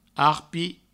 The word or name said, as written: arpi